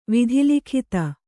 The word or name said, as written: ವಿಧಿಲಿಖಿತ